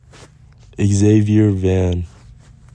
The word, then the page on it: Xavier Van: ex ZAY vyer VAN